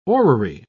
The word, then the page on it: orrery: British English